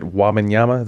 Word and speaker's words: Wembanyama: The coach pool can get deeper if they get Wembanyama.